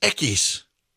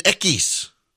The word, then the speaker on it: equis: It's pronounced equis. Latin equis. It doesn't work.